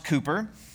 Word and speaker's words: Cowper: It's spelled Cowper, but pronounced Cooper.